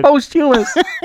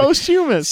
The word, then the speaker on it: posthumous: Posthumous. You put the wrong emphasis on the wrong syllable there. You definitely did. – Posthumous.